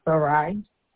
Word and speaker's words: Sarai: Sarai. – Can you spell that? S-A-R-A-I. – Okay. And last name? – Figueroa. F-I-G-U-E-R-O-A.